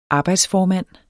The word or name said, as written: arbejdsformand